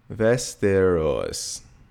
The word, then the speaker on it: Västerås: Västerås.